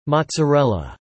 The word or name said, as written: mozzarella